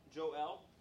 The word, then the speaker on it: Joel: Samuel has appointed his sons Joel, or Joel, if you prefer the Hebrew pronunciation, and Abijah to be judges at Beersheba.